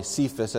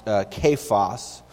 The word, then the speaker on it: Cephas: In verse 11, Paul mentions the name, and we would pronounce it probably Cephas, that's how I've always pronounced it, and then found out it's actually pronounced Cephas.